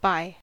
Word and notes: by: American English

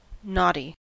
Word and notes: knotty: American English

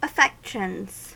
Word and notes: affections: American English

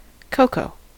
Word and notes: coco: American English

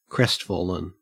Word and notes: crestfallen: Australian English

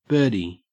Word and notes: birdie: Australian English

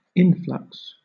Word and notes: influx: British English

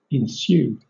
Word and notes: ensue: British English

